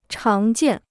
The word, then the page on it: 常见: cháng jiàn